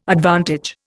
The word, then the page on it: advantage: Indian